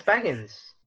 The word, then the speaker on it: Bagans: Why do you keep saying his name wrong? It's like Zach Bagans. – It's Bagans. – You say Biggins. – Did I? It's Bagans.